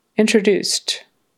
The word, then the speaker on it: introduced: So the accent is on the third syllable, the last one, introduced. And notice that final D becomes a T because the S is unvoiced and it's kind of a blend, introduced.